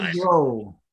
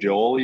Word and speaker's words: Grohl: Dave Grohl. – You know Dave Joel, you said? – Grohl.